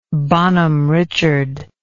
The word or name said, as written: Bonhomme Richard